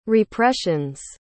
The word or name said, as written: repressions